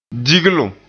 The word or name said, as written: diglum